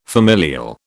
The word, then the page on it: familial: British English